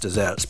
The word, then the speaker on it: deserts: It's spelt as in desert, but it's pronounced dessert, and that's you getting your just desserts.